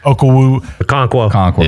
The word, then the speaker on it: Okonkwo: Okonkwo. – Yeah. Okonkwo.